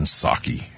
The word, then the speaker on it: Psaki: They have, of course, no jabs, no mandatory jabs, because they're only going to stay here for a while, according to Jen Psaki, okay? The P head is silent there, but so they're not vetted.